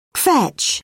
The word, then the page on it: kvetch: British English